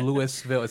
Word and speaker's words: Louisville: It's Louisville. It's spelled Louis and it has a ville at the end.